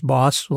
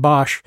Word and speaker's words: Bosch: Anyhow, and then bringing that up to date through Hieronymus Bosch, and the correct way of pronouncing that is boss, like be a boss. So rather than Bosch, even though it looks like that.